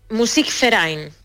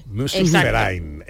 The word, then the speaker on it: Musikverein: Musikverein. – Como si fuera una F. – Musikverein.